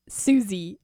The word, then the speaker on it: Siouxsie: And just whenever I hear Susie and the Banshees, I'm like, I know how to spell it because it's spelled differently. Fun fact, it's... not how you think it's spelled. If, you know, Susie, you would think, oh, like S-U-S-I or S-U-Z-I-E. I don't know. But it's actually spelled S-I-O-U-X-S-I-E.